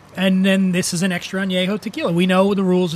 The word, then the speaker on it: extra añejo: Extra añejo.